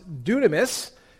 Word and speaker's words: dunamis: The Greek word for power is pronounced dunamis, and we get our English word dynamite from it.